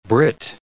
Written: brit

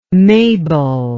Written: mabel

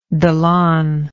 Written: dalan

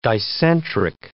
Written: dicentric

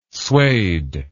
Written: swayed

